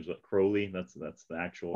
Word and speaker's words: Crowley: And so, you know, like I mentioned, I got that Rigardi book, and then eventually got some of Crowley, whatever you want to say, right? – If you think me holy, if you think me holy, call me Crowley.